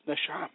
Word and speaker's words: neshama: The Hebrew word for breath in this text of scripture is neshama, pronounced neshama, meaning to puff, mind, angry, or vital breath, divine inspiration, intellect, denoting the soul's deposit, breath, inspiration, soul, spirit.